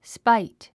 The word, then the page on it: spite: spáit; スパイト